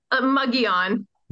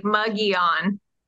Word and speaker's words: Mogollon: So I would have called it Mogollon, but I heard we tried to look it up and it was Mogollon. How do you pronounce it? – Mogollon. – Mogollon. – Like mug as in like a drinking mug, like Mogollon. – Mug, just like it's spelled. Yeah, just like it's spelled. – I think the double L is the yuh.